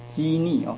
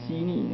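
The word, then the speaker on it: 车匿: 机逆哦，那是一个车子的车了，不过读作破音字，那个机逆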